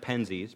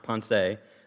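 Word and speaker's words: Pensées: In this book called the Pensée, it looks like Penzies, but it's pronounced Pensée, he says, we seem never to be able to be happy with the present.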